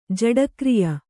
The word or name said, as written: ಜಡಕ್ರಿಯ